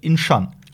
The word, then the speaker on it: Incheon: Das spielt in der Stadt Incheon oder Incheon. Xion? Ach, hier steht sogar die Aus Aussprache. Incheon. Shan. Incheon.